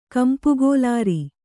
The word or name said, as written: ಕಂಪುಗೋಲಾರಿ